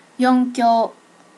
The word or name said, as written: yonkyo